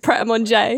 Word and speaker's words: Pret a Manger: You pronounce it Pret a Manger. That's it. Pret Manger, I think, is how you technically pronounce it here.